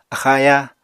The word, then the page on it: axa-ayá: apatani